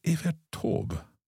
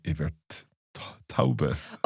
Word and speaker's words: Evert Taube: So is Evert Taube? – Mm? – I have always in my head pronounced that Evert Taube. – Oh, no. Evert Taube.